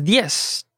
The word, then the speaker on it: diez: And the last number is diez. Just one syllable, JP. – Okay, just one syllable. Diez. Not diez, right? – Diez.